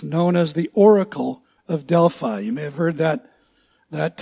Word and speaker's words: Delphi: In the mountains of central Greece is a place called Delphi or Delphi.